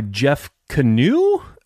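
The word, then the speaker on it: Jeff Kanew: But so yeah, the Troop Beverly Hills from 1989 was starring obviously Shelley Long, directed by Jeff Canoe? Do you know how to say it? Is that right? – That's right, but then watch you and he'll be like, nope, that's not it.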